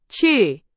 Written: 去

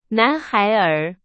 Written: 男孩儿

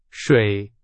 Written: 水

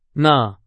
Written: 呢

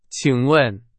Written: 请问

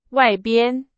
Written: 外边

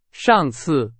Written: 上次

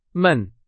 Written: 们

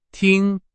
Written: 听

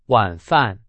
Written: 晚饭

